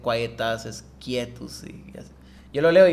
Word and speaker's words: quietus: El quietus, ellos muchos lo pronuncian como quiet as.